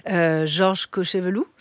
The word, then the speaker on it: Georges Cochevelou: De la diaspora, votre père, Georges Cochevelou, c'est comme ça qu'on prononce.